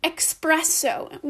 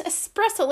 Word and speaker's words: espresso: Like one time, for example, I said espresso when I meant espresso. They all knew what I was saying because they corrected me and said, no, it's espresso.